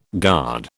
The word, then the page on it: guard: British English